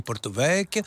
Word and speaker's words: Porto Vecchio: On rappelle, on le dit tout le temps, qu'on ne prononce jamais les voyelles de fin. Hein. C'est bien ça quand on parle la dernière. On ne dit pas Porto Vecchio, on dit Porto Vecchio.